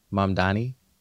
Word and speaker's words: Mamdani: Mamdani.